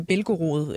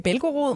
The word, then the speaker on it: Belgorod: Britiske øh, efterretninger har øh, berettet om et russisk øh, oliedepot i øh, Belgorod. Er det sådan, man udtaler det? Øh, cirka? – Altså nogenlunde.